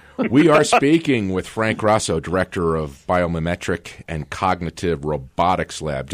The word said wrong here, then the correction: biomimetic: Did I pronounce everything correctly? – It's actually the biomimetic. – Mimetic. – Biomimetic. Okay.